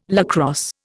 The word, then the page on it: lacrosse: Indian